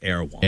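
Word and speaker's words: Erewhon: How do you say it? I don't know. Erwan. You're right, Chuck. Erwan.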